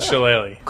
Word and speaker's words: shillelagh: The captain of the winning team is beaten with the jeweled shillelagh. – Question, pronouncing shillelagh, right? – Shillelagh.